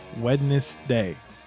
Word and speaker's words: Wednesday: Wednesday. – Wednesday. If you say it how it's spelled, that's what it says. Wednesday. – But we say Wednesday. We put the N before the D.